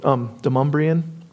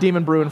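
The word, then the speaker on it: Demonbreun: You know that the road called Demumbrian? It's spelled Demon Bruin, and I called it Demon Bruin forever.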